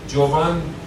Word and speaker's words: جوان: جوان درستره، یعنی تلفظ کهنش جوانه، یوانه است، بعدش هم شده جوان، بعد هم شده جوان.